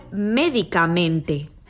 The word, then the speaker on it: médicamente: Quizá les resulte más claro observando el contraste que se produce entre el nombre medicamento y el adverbio médicamente.